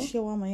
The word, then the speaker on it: shawarma: That's how you say it, right? Shawarma? – Shawarma, yeah.